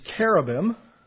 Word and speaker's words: cherubim: The next one we want to look at is classification is cherubim, with a K. It is spelled C-H-E-R-U-B-I-M.